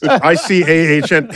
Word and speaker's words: Icahn: ICAHN.